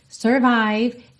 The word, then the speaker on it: survive: Aside, survive, elas rimam e o E final não é pronunciado em nenhuma dessas duas palavras, ok?